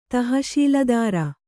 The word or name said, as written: ತಹಶೀಲುದಾರ